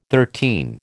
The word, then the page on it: thirteen: American English